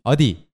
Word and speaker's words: 어디: Here, although we romanize the word 어디 with E-O and D-I, the English alphabet D-I, please make sure that your pronunciation of the second letter, D, is not actually D-I, but you have to make the T-H kind of like T-H sound.